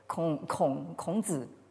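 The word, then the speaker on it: Krongzi (孔子): Alors, c'est même pas la peine de connaître le chinois pour s'apercevoir que le mot zhu n'a rien à voir avec le nom de Confucius qui se prononce en chinois. Krongzi.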